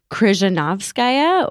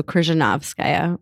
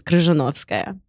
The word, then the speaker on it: Krizhnevskaya: And if you want to pronounce my name correctly, it's Krizhnevskaya, or the accents on the knob. So Krizhnevskaya. Or if we were saying it in Russian, it's Krizhnevskaya.